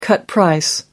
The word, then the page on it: cut-price: American English